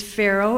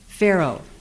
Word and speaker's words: Farrow: The title of the poem is Pharaoh. It's spelled F-A-R-R-O-W, not the ancient Egyptian ruler. Pharaoh.